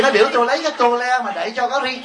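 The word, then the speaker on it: tôle: Tấm tôn á, hồi xưa cái tôn của Việt Nam Cộng Hòa mình là chỉ tôn là không, chữ T chứ O L E thì nó đọc là cái tô le đẩy cho cái ri ke.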